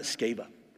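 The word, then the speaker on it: Sceva: I pronounce it Skeva. Some people will pronounce it Skeva, some Skeva. I call it Skeva because it reminds me of the word scabies.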